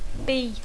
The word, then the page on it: be: English